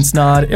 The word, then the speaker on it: Snaadt: My name is Ryan Snod. It rhymes with odd, and you're listening and watching the Rhymes with Odd podcast.